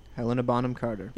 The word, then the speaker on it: Helena Bonham Carter: am I going to say her name right? Helena Bonham Carter? – Helena Bonham Carter.